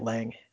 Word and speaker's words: Lange: Is that how you say his name? Lang. I say it Lang. L A N G E.